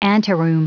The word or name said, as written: anteroom